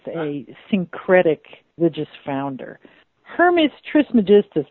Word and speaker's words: Trismegistus: That's how I would pronounce it. – Trismegistus.